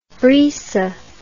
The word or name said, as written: brisa